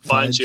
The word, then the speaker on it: Fiennes: Fiennes, yeah.